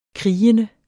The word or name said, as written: krigende